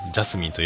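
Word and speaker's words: 茉莉花: 何ですかマリカと書いてですかこれ何て読むんですかねジャスミンと読む。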